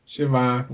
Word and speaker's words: Siobhan: Shavon. I'm trying to think of how to say her name. – Siobhan, whatever.